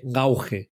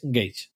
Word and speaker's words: Gauge: Ahí, tiene, tiene Venga, luego tenemos a Álvaro, que, me puso muy amablemente en el canal la pronunciación de Gauge. Que yo no sé por qué, siempre mi cabeza está pensando en gauge y es Gage.